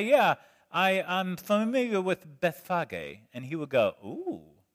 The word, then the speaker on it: Bethphage: I've always heard Bethpage. It's Bethphage.